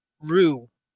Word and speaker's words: Ryu: The way it's pronounced in Japanese is just yeah. – Ryu.